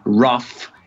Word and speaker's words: rough: Per esempio, nella parola rough e tough si pronuncia come una F, nella parola tipo thorough invece c'è un GH finale che non viene pronunciato.